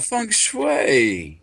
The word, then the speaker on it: feng shui: As a great guest, it's on, I'm going to say it right, feng shui. Did I say it right? – Oh, feng shui. Yeah, that's something I don't know much about and always wanted to learn more.